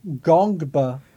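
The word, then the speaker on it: Ngongba: Patrick Gongba, but there's an N at the beginning of his last name, a silent N.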